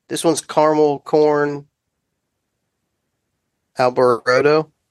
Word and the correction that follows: alboroto: Uh, Alboroto. Alboroto. Yeah, you have to like pronounce the T. You have to pronounce it right.